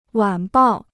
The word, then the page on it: 晚报: wǎn bào